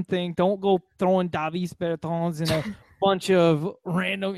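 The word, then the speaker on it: Bertāns: But I do believe it's pronounced Davis Bertans.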